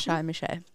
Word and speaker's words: Charles Michel: Derzeit ist der Präsident des Rats, auch mal wieder ein Mann, Charles Michel. Habe ich das richtig ausgesprochen? – Charles Michel. – Sehr schön.